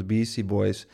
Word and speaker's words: Beastie Boys: Zijn grote claim to fame naast het ontdekken en het grootmaken van de Beastie Boys is de geweldige... – Kijk, dat spreek je nou net zo uit als podcast.